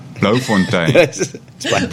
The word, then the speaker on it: Bloemfontein: How many schools are in Bloemfontein? Did I say it wrong? – Bloemfontein. Blue Fountain. – You can just call it Bloem.